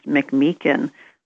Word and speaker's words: McMeekin: My last name is pronounced McMeekin.